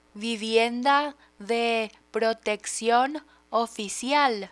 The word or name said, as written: vivienda de proteccion oficial